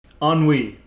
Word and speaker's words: ennui: No, ennui, that's the word. I completely mispronounced it.